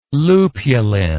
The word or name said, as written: lupulin